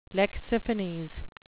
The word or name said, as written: lexiphanes